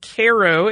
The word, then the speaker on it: Cairo: He said, to hear you mention that the Sultana stopped in Cairo, and he says the locals pronounce it Cairo, Illinois, was interesting.